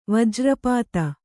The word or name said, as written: ವಜ್ರಪಾತ